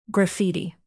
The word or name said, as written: graffiti